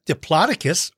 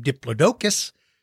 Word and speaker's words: Diplodocus: You know, Brachiosaurus lived with Diplodocus, or you might pronounce his name Diplodocus.